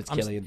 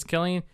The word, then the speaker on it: Cillian: Is it Killian or Cillian? Because I'm Killian. – It's Killian... I think I've been saying it wrong all these years. – Because yeah.